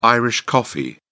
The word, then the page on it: irish coffee: British English